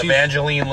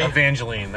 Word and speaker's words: Evangeline: Evangeline Lily? – Evangeline, that's how you say it.